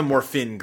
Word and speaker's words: Morphin: I thought you were mad at him for saying it wrong, because it's not actually supposed to have the G there. – That's true. It is the Morphin Grid.